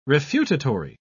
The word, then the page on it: refutatory: British English